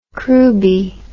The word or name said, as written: crewby